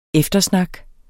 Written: eftersnak

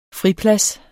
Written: friplads

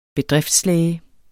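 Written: BEDRIFTSLÆGE

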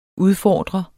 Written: udfordrer

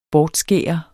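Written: bortskær

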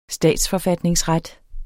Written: statsforfatningsret

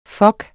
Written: fok

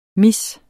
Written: miss

